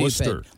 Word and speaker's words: Worcester: Well, what's that city outside of Boston that people, I know I've been there, that is that looks like it would be pronounced one way, but it's pronounced stupid. – Worcester.